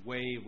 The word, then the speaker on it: Hue: Sorry, it's Way Restaurant, HUE, which is currently on KK, and they're opening up a second location at this facility. – I would have never pronounced that right, so thank you very much.